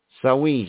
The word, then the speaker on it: Samhain: Samhain, it's pronounced, but it's spelled Samhain or something like that.